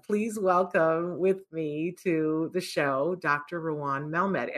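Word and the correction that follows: Raun: First of all, it's R-A-U, so it's rhymes with Sean. So think of Sean and then say Ron. – Okay, Ron.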